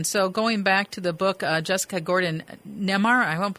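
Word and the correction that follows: Nembhard: I'm pronouncing her last name correctly. – Nemhart.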